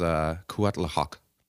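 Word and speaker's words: Cuitláhuac: I'm actually usually all right with that one, but this was Cuatlahoc. – Oof. – That sounds pretty good.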